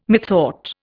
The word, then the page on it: methought: Indian